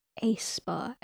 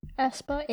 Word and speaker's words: aespa: It's ASPA. It's the AE together, like that. – ASPA. – Yeah, ASPA. ESPA, ASPA. – ASPA. – ESPA. – Because it's the same, the lotion store is spelled the same but with an O, and it's ASOP. That's how they pronounce it.